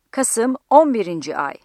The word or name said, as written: Kasım